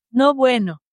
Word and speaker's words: no bueno: We're both saying it wrong. I just like to share my screen for a second here. – How do you say it? – Listen, I'll have Google. Ready? – Mm-hmm. – No bueno. – Did you hear that? – Yeah, well, that was pretty fucking close, dude.